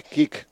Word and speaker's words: Kick: Necesitamos 90 seguidores en Kit, por favor. – Kick. – En Kick. Eso es, sí, lo digo mal a propósito y al final lo termino diciendo mal.